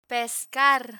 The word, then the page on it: pescar: Spanish